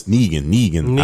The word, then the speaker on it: Negan: Oh, his name is Negan. I was like, I knew it was something like a single syllable. – I'm sorry. – No, that's two syllables.